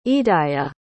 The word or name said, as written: idaia